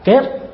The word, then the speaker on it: kết: Chữ kiết ở trong chữ Hán này đó, nó đúng đó, đọc đúng nghĩa đó, đó là kết.